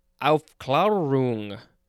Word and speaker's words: Aufklärung: Yep, it's German, and it is pronounced Aufklärung. Aufklärung, something like that. It is spelled capital A-U-F-K-L-A, and the A has an umlaut, R-U-N-G.